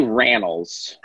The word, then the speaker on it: Rannells: Well, it's actually Rannells. – Rannells. – The long A.